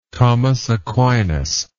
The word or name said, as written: thomas aquinas